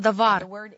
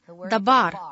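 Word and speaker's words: давар (דבר): Это слово «давар». Оно также произносится как «дабар».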